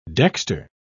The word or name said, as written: dexter